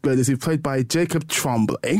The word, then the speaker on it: Tremblay: I think it's Tremblay, isn't it Tremblay? I don't think I'm mispronouncing it. This isn't a Bernard type situation. I'm pretty sure it's Tremblay.